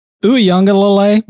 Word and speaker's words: Uiagalelei: But Uiagalelei, by the way, is how you say his name.